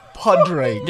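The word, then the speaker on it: Pádraig: Podraig. – Podraig. – Podraig is going to be one of the... Because it's meant to be Patrick, right? – Podraig. – Yeah. – How do you spell that? – Podraig. P-O-D-R-A-I-G.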